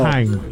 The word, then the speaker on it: Mannheim: De acordo com ele, a cidade de Mannheim, provavelmente não é assim que se pronuncia no meu sotaque alemão, peço perdão. – Não, tá ótimo teu alemão. – Mannheim. – É isso aí mesmo.